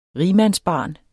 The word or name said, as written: rigmandsbarn